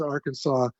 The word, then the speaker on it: Arkansas: I never understood why you guys pronounce it Kansas and they pronounce it Arkansas, but that's one of the wonderful subtleties of the English language.